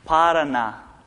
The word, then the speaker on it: Paraná: The second word, Paraná.